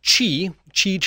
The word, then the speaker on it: Qi: Yeah, I mean, it's pronounced Qi charging, and this is something that's actually been an industry standard.